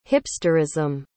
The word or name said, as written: hipsterism